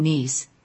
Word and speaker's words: Nice: You have Nice, which is a homograph because it can be also pronounced as nice, because that's a standard English word, and so on, so on.